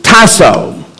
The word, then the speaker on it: tasso: And so the prefix is hupo. You would spell this in English, H-Y-P-O. And the suffix is tasso. You would spell it T-A-S-S-O.